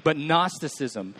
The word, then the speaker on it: Gnosticism: It starts with a G. There's a silent G in there if you're trying to take notes. But Gnosticism.